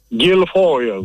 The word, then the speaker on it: Γκυλφόιλ: Για πείτε μου το όνομα Γκυλφόιλ, ναι. Έχετε μια ειδική έτσι. Προφορά. – Γκυλφόιλ, όπως λέτε και εσείς.